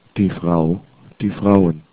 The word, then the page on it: frau: german